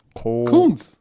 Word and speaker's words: Koonce: K-O-O-N-C-E. Wie spricht man das aus? – Kunz. – Kunz, okay. – Kunz. – So, Malcolm Kunz. – Doppel-O ist doch U in Englisch.